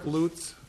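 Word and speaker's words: Lucht: My name is Chuck Lutz.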